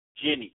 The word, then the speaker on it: jinni: J-I-N-N-I. Jenny. Pronunciation is J-E-E-N-E-E. So that's Jenny, right?